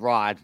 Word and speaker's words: Rad: It's spelled R-A-D, but it's pronounced Rod. So it's not Rad? No, it's not Rad. It's Rod.